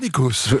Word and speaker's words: Nikos: Mais bravo Nikos. – J'ai bien prononcé votre nom.